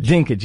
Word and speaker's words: Dinklage: I think it's Peter Dinklage. – Dinklage, yeah, that was good.